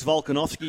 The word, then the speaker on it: Volkanovski: Spot on, Alex Volkanovski. You pronounced it spot on, Michael Craig.